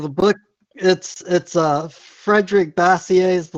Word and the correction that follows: Bastiat: The Law, and his last name is French, so it's actually Bastiat. So I believe it's pronounced Bastiat.